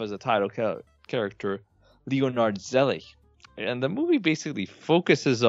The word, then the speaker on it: Zelig: We're going to talk about 1983 Zelig, or Zelig, if you want to pronounce it correctly, in a German pronunciation.